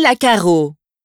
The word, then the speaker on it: Lacarrau: Je m'appelle Marie Lacaro. L-A-C-A-R-R-A-U. – 2. Bonjour, je m'appelle Benjamin Lafitte. Mon prénom est B-E-N-J-A-M-I-N.